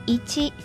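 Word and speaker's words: いち: 数字一在日语当中可以读一七，取第一个发音，两个组合在一起就变成了一，表示好的意思。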